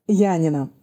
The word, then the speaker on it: Янина: Янина, город Янина. – Янина. Я потом погуглю обязательно. – Да, по-гречески он называется Иоаннина. Это тоже интересно. Студенты узнают, что их город как-то по-другому читается по-русски и пишется.